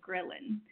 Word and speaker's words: ghrelin: It sounds like gremlin, but it's like ghrelin hormone.